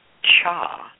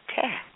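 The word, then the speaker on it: cha (茶): And the most of the dialects of Chinese, it is pronounced cha or chai. But in the central coast of China and in Southeast Asia, it is pronounced ti or te.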